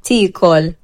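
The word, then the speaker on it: tiekol: Il verbo è mangiare, che in maltese si dice così. – Ticol, ticol, ticol, ticol.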